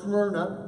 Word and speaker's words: Smyrna: And we learned it was called Smyrna. You got to say it like that, all right? It's the only way to say it.